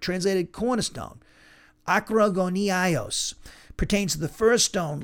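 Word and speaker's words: akrogoniaios (ἀκρογωνιαῖος): Now, the adjective akrogoniaios, that's how you pronounce it, akrogoniaios, is translated cornerstone.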